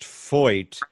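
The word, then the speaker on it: Feucht: It's pronounced Foyt. But it's spelled F as in Frank, E-U-C-H-T, F-E-U-C-H-T, but it's pronounced Foyt, like F-O-Y-T.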